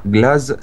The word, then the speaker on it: Glaze: Pour tous ceux qui veulent faire des recherches, on a Truman Pierce, P-I-E-R-C-E, et on a Glaz Sacra, G-L-A-Z-E, Sacra, comme ça se prononce.